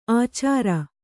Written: ಆಚಾರ

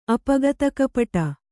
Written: ಅಪಗತಕಪಟ

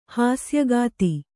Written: ಹಾಸ್ಯಗಾತಿ

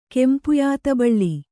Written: ಕೆಂಪುಯಾತಬಳ್ಳಿ

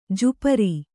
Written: ಜುಪರಿ

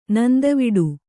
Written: ನಂದವಿಡು

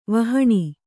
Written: ವಿಹೀನೆ

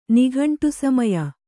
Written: ನಿಘಂಟುಸಮಯ

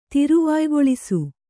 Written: ತಿರುವಾಯ್ಗೊಳಿಸು

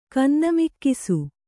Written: ಕನ್ನಮಿಕ್ಕಿಸು

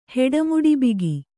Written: ಹೆಡಮುಡಿಬಿಗಿ

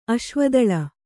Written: ಅಶ್ವದಳ

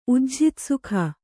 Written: ಉಜ್ಝಿತಸುಖ

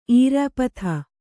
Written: ಈರ್ಯಾಪಥ

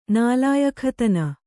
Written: ನಾಲಾಯಖತನ

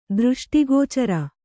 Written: ದೃಷ್ಟಿಗೋಚರ

